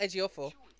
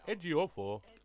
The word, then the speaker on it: Ejiofor: You got to do it with an African accent. Edgy-o-fo. – Chiwetel Ejiofor. – Ejiofor.